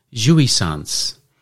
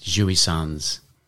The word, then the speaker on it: jouissance: And this week's Word of the Week is jouissance. And that is spelt J-O-U-I-S-S-A-N-C-E.